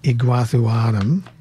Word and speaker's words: Iguazuanum: So they do flower the same as that group, but they're into specific hybrids like Aulicum crossed with Iguazuanum. I'm going to spell that. I-G-U-A-Z-U-A-N-U-M, Iguazuanum, as in Iguazu Falls.